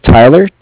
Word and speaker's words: Tyler: My name is Tyler, T-Y-L-E-R, and I'm calling with Cardiff. Like I said, it's spelled C-A-R-D-I-F-F, as in Frank, Frank.